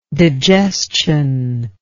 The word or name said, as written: digestional